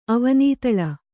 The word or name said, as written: ಅವನಿತಳ